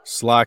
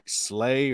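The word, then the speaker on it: Schley: I called it like Slay or Slay. – Yeah, for sure. – And they're like, no, it's Sly. Sly County. I was like, okay. All right. Gotcha. Sly County High School.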